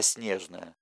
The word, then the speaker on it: Снежное: Дед при жизни всегда, чуть потеплев, прищуренной сеточкой вокруг глаз, говорил не так, как принято, а Снежное, с ударением на первом слоге.